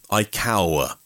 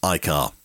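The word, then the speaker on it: iCAUR: So they've added a U, iCower. But it's meant to be pronounced iCar.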